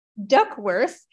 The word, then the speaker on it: Duckworth: I keep wanting to say Duxworth, but that is wrong. And I've already said it wrong once. So everyone, it's Charlotte Duxworth.